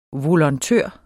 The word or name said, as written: volontør